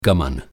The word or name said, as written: गमन